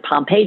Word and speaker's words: Pompe: And one of those disorders was Pompe disease, or some people pronounce it Pompe disease,